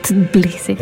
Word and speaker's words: Tbilisi: Tbilisi. – Tbilisi. Sier du bare Tbilisi? – Tbilisi. – Ja, for det ikke noe sånn stum T eller noe. Det jo veldig corny med T-B.